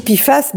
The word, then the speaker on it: PFAS: Je viens d'écouter la rediffusion de Bionutrition sur les PFAS. – Mais on dit PFAS même si ça s'écrit PFAS.